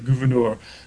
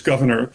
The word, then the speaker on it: Gouverneur: People did not pronounce it in the French way, gouverneur, but one of Morris's ancestors suggests it was just governor, the way I've been saying it.